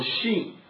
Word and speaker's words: Chine: 所以呢，那么如果你按照法语的读音的话，那么那这个因为原来是什么，原来的这个法语是 CHINE，那如果你按照法语的读音的话是 Shin，但是呢最后你如果按照美国你从英国人角度来读要发 CHINE 的话，就后来就变成 China。